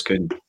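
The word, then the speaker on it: Kühn: She came back with a voice note saying, this is how you pronounce it, because it's got the umlaut above the U. So, Nicolas Kuhn.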